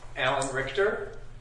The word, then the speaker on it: Alan Richter: Alan Richter, 1023 Wesley Avenue. – Can you spell your last name, please? – R-I-C-H-T-E-R.